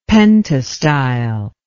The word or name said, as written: pentastyle